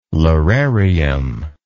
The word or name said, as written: lararium